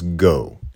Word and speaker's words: Geau: And the gut is Go, G-E-A-U. He would spell it G-O, but he's sort of got influenced by Henri, who wants to spell it, you know, the French way because he's sophisticated.